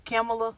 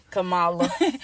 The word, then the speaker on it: Kamala: Kamala. – Kamala.